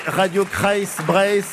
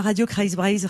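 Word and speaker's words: Radio Kreiz Breizh: Bonjour, RKB, mais c'est Radio Kreisbreise. – C'est ça. – Je le prononce pas trop mal. – Non, c'est bien Radio Kreisbreise, Radio Centre-Bretagne.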